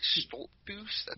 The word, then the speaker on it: Stoltzfus: I mean, listen, Stoltzfus is a... Did I say that right? Stoltzfus? – I think you got it.